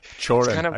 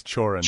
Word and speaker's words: Cioran: It's maybe a little bit mysterious to me, but I do think there's a way by which there's a thinker named, I don't even know if I'm pronouncing his name right, Emile Cioran, I think. – Chorin. I think it's Chorin.